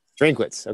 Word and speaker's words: Drinkwitz: How do you say that? – Drinkwitz. – Okay.